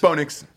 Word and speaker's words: Bo Nix: There's too much of a pause in between Bo and Nix. – It's Bo Nix. – It's just a one syllable. – Bo Nix. Well, no. It's two syllables, but it's one word.